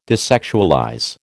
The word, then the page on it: desexualize: American English